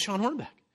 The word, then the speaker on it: Sean Hornbeck: Did I say his name right? Yeah, it's Sean Hornbeck.